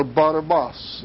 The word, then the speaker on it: Barabbas: And at that time, they had a notorious prisoner called Barabbas, or Barabbas, as it's correctly pronounced.